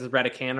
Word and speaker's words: Redican: No. I said it wrong. I was calling it Redcan the whole time. – Oh, I would imagine it's Redican, or they just have an extra letter just to fool people.